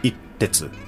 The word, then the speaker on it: Itetsu: So in in Japanese way of uh, pronunciation, it's itetsu. – Mm -hmm. – But when I write it in English, mm -hmm. it it turns into itetsu. – -hmm. – So it's a little bit different.